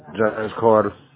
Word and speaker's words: James Carter: James Carter. – Can you spell it, please? – J-A-M-E-S-C-A-R-T-E-R.